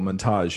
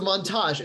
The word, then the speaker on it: montage: It's a montage. It's a French word. The accent is on the second syllable.